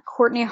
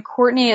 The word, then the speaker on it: Cortney: My Twitter is at Cortney Harding, and Cortney is spelled C-O-R-T-N-E-Y. Harding is H-A-R-D-I-N-G.